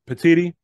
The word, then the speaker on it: Petitti: Is it Tony Petitti, the Big Ten commissioner? – Yeah. – Petitti.